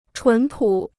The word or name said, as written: 纯朴